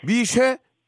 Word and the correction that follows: 미세: 예, 예 미세. 자, 발음을 제대로 해주시기 바랍니다. 많은 국민들이 듣고 계십니다. 미쇠가 예. 아니고 미세.